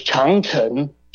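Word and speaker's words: Changcheng (长城): Was Changcheng. Chang means long. Cheng means a castle. So Changcheng.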